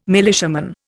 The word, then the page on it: militiaman: Indian